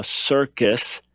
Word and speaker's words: circus: You have a soft C at the beginning, S, and a hard C in the middle, C, circus.